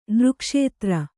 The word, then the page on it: ನೃಕ್ಷೇತ್ರ: kannada